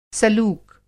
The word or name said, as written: सलूक